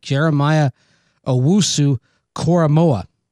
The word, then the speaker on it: Jeremiah Owusu-Koramoah: Jeremiah Owusu-Koramoah.